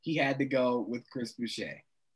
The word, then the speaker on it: Chris Boucher: But another guy that I've been thinking about is Chris Boucher. Is that how you say his? – Chris Boucher.